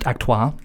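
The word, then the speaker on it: acteur: It's pronounced actoir. – I've actoired it before. – Actoir, right? – I worked with a Royal Shakespeare Company.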